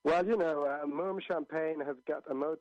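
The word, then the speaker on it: Mumm: Yeah, and I want to say it's pronounced Moom up north of the border itself, and the border here in Mom, we'll refer to it here as Moom so people don't get confused.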